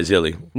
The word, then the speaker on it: Zilly: Zilly. – Zilly? – Yep. – Is that how you pronounce it? – Yeah, Zilly. – Not Zeal. – Like Philly, Zeal. Like Silly, like Billy.